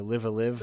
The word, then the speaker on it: Live A Live: So it was kind of like a, now luckily, because they're releasing it in the US finally, it was basically, it was basically Live Alive or Live Alive. I forget which it is. Or Live a Live, however you pronounced it.